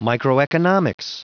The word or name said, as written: microeconomics